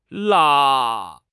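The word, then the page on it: la1: tone 1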